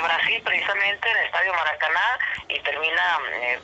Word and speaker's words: Maracanazo: ¿Maracanazo? – ¿Qué Maracanazo, sí.